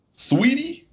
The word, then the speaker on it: Saweetie: Sweetie, I think her name is pronounced sweetie, but it spells salty.